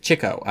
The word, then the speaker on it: Chico: Or actually, it's supposed to be pronounced Chico, I hear. – Yes. – Even though I was saying it wrong for a long time, but I learned from the audio commentary, everyone says it wrong. It's actually Chico.